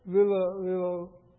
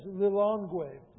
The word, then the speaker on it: Lilongwe: The church was asked to support the building of a pediatric hospital in Malawi, Africa, in the capital city of Lilongwe. I'm saying that name wrong. There it is, Lilongwe, Malawi, Africa.